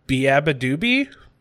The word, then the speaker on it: beabadoobee: He amassed popularity following the release of his first charting single, Deathbed Coffee for Your Head, featuring beabadoobee, is how you say her name, uh, which peaked at number 23 on the Billboard Hot 100.